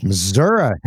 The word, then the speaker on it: Missouri: Missouri. Hey, you know how to say it.